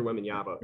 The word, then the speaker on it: Wembanyama: No, dude, I even I think it's Wanyama. Wanyama. – Yeah, Victor Wembanyama. Victor Wembanyama.